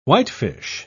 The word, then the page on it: whitefish: British English